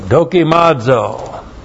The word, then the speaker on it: dokimazo: D-O-K-I-M-A-Z, long O, dokimazo.